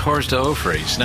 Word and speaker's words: hors d'oeuvres: you, you, what you do is you, I think in French it's pronounced hors d'oeuvres.